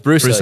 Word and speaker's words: BrUSA: So it's Bruce. Yeah, yeah. – Bruce. I love the SA, yeah. USA, Brew, all of it together.